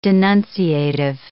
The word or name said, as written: denunciative